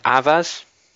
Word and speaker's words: Avaaz: The group, I think, is Avaz. I think that's how you pronounce it, A-V-A-A-Z, if you want to check it.